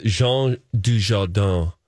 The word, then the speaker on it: Jean Dujardin: The Artist with Jean Dujardin.